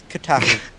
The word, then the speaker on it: Kotaku: We also have a little bit of a video that we watched, and if you guys want to see it, it's on, how do you pronounce this, Kotaku? I never do that right. – Yeah, it's Kotaku.